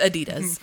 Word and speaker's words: Adidas: As a moment to recognize that you said it right. – Because I like to say Adidas. – It's Adidas.